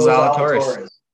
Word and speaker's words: Zalatoris: Is that how you pronounce his last name? – Yeah, Will Zalatoris.